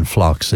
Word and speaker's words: phlox: P is silent in phlox. It's P-H-L-O-X.